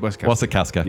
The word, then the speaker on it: Wasikowska: Wasikawa? Wasikowska? I can't say her name. – Wasikowska.